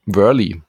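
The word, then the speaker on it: Wehrle: Cole Wörl, oder wie spricht man denn aus? – Cole Wörli. – Wörli. – Ja.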